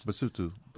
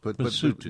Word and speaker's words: Basotho: And this is from the, it's spelled Basotho, but how do you say it? – Basotho. Basotho. Yeah, they speak a language called Sotho.